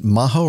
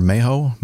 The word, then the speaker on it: Maho: So is this pronounced Maho or Mayho? – Mayho. – Mayho. Princess Juliana Airport in St. Martin.